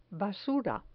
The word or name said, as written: basura